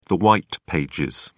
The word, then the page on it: white pages: British English